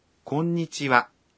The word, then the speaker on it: こんにちは: こんにちは。 – 你好。这个问候语的哇也是写作哈，但是读作哇，和刚才呢是一个道理。